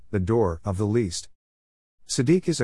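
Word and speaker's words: tzedek (צדק): It is derived from a three-letter verb root, tzedq, pronounced tzedek, meaning righteous, to be right, just, or upright.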